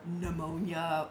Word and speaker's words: pneumonia: And the not dying of, you know, malaria or pneumonia or stuff. We think people still die today. – Could you please pronounce the P in that word? – Pneumonia.